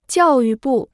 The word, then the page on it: 教育部: jiào yù bù